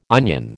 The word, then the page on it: onion: American English